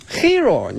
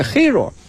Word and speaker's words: hero: 你把那天我看一个片子， A Hero，我女儿说 Hero，你发音不对，Hero，是吧，Hero，所以我发现老师他就没办法了，现在就是你怎么，你给我下多大功夫来学，我都纠正不过来了，就是这样一个问题。